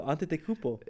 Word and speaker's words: Antetokounmpo: So on the East, we have Trae Young, DeMar DeRozan, Kevin Durant, Giannis Antetokounmpo, and Joel Embiid. – Butchered Giannis' last name. – No, Antetokounmpo. – I know, but you said it weird, bro.